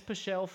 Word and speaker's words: Pichel: Pichel? – Vince Pichel from hell.